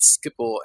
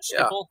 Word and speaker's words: Schiphol: We are here on location in Amsterdam at Schiphol Airport. So how you pronounce it? – Something like that, Schiphol.